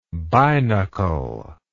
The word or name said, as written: binocle